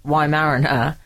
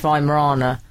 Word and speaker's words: Weimaraner: I called it a Weimaraner, and apparently it's Weimarana.